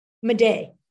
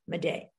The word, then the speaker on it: Madej: How you pronounce her name? – Madej, M-A-D-E-J, Madej. – Madej, okay.